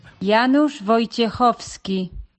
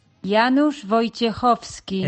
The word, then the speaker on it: Janusz Wojciechowski: Creo que tienes ahí cómo se pronuncia en polaco el nombre del candidato a comisario de Agricultura. A ver. – Janusz Wojciechowski. – Pónmelo otra vez, que me ha entrado la risa. – Janusz Wojciechowski. – He tenido que llamar a una polaca para que nos lo tradujese o lo pronunciase.